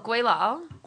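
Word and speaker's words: gweilo: Oh, guilao.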